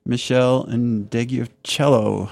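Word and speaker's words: Meshell Ndegeocello: and he was the producer for Michelle Indegiocello. Help me pronounce it. – Yeah, that's right. Yeah, you got it.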